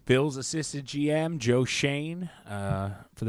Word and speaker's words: Schoen: You assume it's pronounced like a Jewish name. But it's not. It's apparently Schoen, and that's Midwestern.